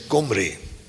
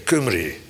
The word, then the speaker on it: Omri: Omri, Komri. Met een aaien aan het begin, Komri. De Komri, de Kimri. Afhankelijk van hoe je die direct wil uitspreken, Kumri.